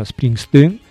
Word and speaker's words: Springsteen: E poi soprattutto di origini olandesi, infatti il suo nome è Springsteen, che si pronuncia poi in olandese, si pronuncia Springsteen, è un tipico cognome della zona di Utrecht, da dove viene la sua famiglia.